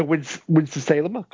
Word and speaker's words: Winston-Salem: We shall go back to Winston-Salem. Have I said that right? – Yeah.